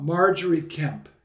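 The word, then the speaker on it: Margery Kempe: But in your book, we get a lot about Marjorie Kemp. Is that, that's the way to pronounce it? – It is.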